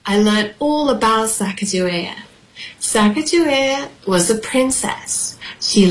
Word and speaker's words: Sacagawea: She has a fancy name, Sacagawea. You say it like this, Sacagawea, Sacagawea.